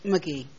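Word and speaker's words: Magee: McGee. – McGee. – Yes. – Those are two E's. – It's M-A-G-E-E. It's not spelled like the normal McGee.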